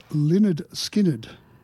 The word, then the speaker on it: Lynyrd Skynyrd: It's Lynyrd Skynyrd.